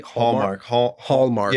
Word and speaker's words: Hallmark: Am I saying it right? – Hallmark. Yeah, Hallmark.